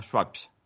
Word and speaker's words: Schwab: Schwartz tiene ahí una S-H-W-A-B. Schwartz. No sé cómo pronunciarlo. S-C-H-W-A-B. – Saskatchewan. – Victoria Schwab.